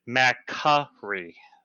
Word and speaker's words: McCree: she specifically said, "Please pronounce it McCree."